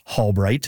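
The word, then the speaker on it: Halbrite: That's so interesting, and I can confirm it's Hallbright. It's spelled Halbright.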